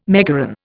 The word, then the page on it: megaron: Indian